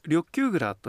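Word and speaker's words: 緑丘蔵: それから緑の丘の蔵と書いてこちらは何と読むんでしょうか緑球蔵というふうに読むんです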